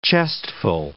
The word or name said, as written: chestful